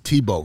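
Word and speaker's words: TBO: Once again, that's T-B-O, and pronounced Tebow.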